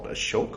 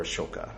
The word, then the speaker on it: Ashoka: Yes, that is from a movie called Ashok, which you can see here, or Ashoka. It's they don't pronounce the A usually, unless they're talking to foreigners, so that confuses us even more and makes us think that everything's pronounced with A, and then we don't understand when people are talking amongst themselves.